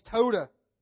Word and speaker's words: Towdah: T-O-W-D-A-H. Todah.